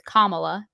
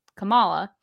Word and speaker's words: Kamala: So Kamala Khan, not to be confused with Kamala, because it is spelled the same way that Vice President Kamala Harris spells it, but it's pronounced Kamala.